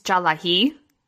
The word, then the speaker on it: Tsalagi: It's funny because in like my heritage, I am considered Cherokee, but that's not how you say it. It's Jalahi, and it's spelled T-S-A-L-A-G-I.